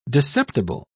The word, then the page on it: discerptible: British English